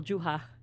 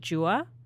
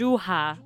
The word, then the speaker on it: Juha: You pronounced it very well, Juha. Because my granddad told me off because I was pronouncing it Jua. And he was like, that's like an English way to pronounce it. – Oh, really? – It's Juha. Juha.